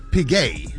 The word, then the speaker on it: Piguet: Is it Piguet or Piguet. – Yeah. Oh, it's fancy. Piguet. Yeah. I always thought it was Piguet. Thank you for correcting that. How you say it again? – Audemars Piguet.